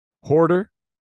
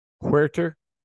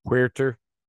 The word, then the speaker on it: Huerter: Hoarder, Huerter. I feel like we're always messing up this pronunciation. I hear people say all kinds of things for this guy. Huerter.